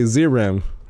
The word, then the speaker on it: Zeiram: Actually, in the movie, they pronounced it. I heard them say Zeram. – Zeram. Zeram 2. – But it's weird because it's Zeram, Z-E-I-R-A-M, on the movie itself, but then on IMDb, it has a U at the end.